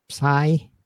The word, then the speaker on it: Psy: uh how do you pronounce his name? Psy.